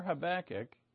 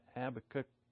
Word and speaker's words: Habakkuk: Turn in your Bibles to Habakkuk or Habakkuk. It does. I guess if both of them work. I looked it up on Google today. It can be pronounced either way. Habakkuk.